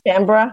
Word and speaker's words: Canberra: And the pronounced Canberra. – Canberra? – Canberra? Is that what it is? – Yeah, Canberra.